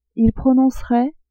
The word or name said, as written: il prononcerait